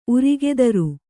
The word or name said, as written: ಉರಗಧರ